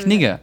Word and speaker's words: Knigge: Sag mal Kniegen, ich bin mega verwirrt. Knige. Knige, okay, ja. so spricht man das aus.